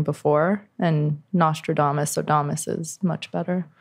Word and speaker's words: Nostradamus: I mean, it's so early for how I use, you know, I don't know how to say it exactly, Damus. Astrodamus. – Nostradamus. – Nostradamus, thank you.